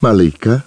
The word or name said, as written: Malika